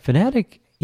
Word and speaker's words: Phanteks: So, Phanteks, is that how you pronounce it? – Phanteks, yes.